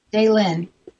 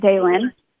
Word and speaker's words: Daylin: Okay, so Daylin, did I say it right or wrong? – Daylin. – Daylin. – You can say it Daylin. – Mm-hmm. No, I want to say it right like you do, Daylin.